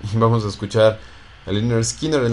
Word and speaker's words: Lynyrd Skynyrd: se pronuncia Lynyrd Skynyrd. Es una gran ayuda que nos enseñan a cómo pronunciar su nombre correctamente.